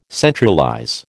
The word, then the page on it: centralize: American English